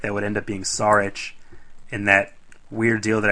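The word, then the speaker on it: Šarić: Also, make sure you're pronouncing it's Saric. – Saric. – Come on, Mark Zumoff doesn't break his lisp every single game pronouncing it that way for nothing.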